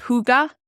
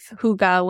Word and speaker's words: hygge: There is a word called hygge, and it is a part of the Danish culture. Hygge is spelled H-Y-G-G-E,